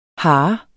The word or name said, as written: hare